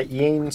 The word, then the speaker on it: Iain: How do you pronounce this? I-A-I-N. – I think it's Ian. – I think it's Ian.